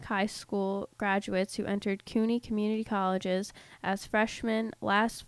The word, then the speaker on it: CUNY: Rick here sent me this article about, it's called City High School Grads at CUNY, CUNY is how you say it, needing remedial education.